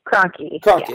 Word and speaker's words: Kroenke: I can never pronounce this guy's last name, so help me out because I'm sure you know. Rams owner Stan Kroenke? – Kroenke, yeah.